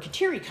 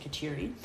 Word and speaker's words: Kateri: And many people want us to pronounce it Kateri, but I'm from the Midwest, and so you just fall back in Midwestern pronunciations. So please forgive us for those that want us to pronounce it as the Native Americans do, which is Kateri.